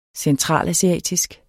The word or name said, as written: centralasiatisk